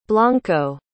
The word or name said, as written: blanco